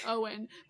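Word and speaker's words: Eoghan: Oliver, or at Oliver_alien on Twitter, that the person we were talking about, who we pronounce his name, Eogan, is an Irish name, and it's actually pronounced Owen.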